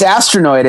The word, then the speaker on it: Astronoid: Please pronounce it appropriately for me. Astronoid, is that? – Yeah, it's Astronoid. It's exactly how it sounds.